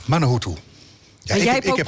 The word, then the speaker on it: Manuhutu: En we hebben, eh, ik ga het goed uitspreken, Johnny Manuhuta. Mahuta. Manuhuta. – Bijna goed, hè? – Oh, wat erg, Johnny.